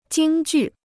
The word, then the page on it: 京剧: jīng jù